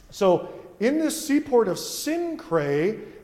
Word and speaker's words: Cenchrea: So what he does, he goes to a place called Cenchrea. That's how you pronounce that, Cenchrea. If you try to pronounce it like phonetically, how it's spelled, you end up with some people think you're speaking in tongues.